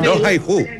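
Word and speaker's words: Rohayhu: Aprenda a decir, por ejemplo, Roja y Ju significa te quiero, te amo. – Roja y Ju.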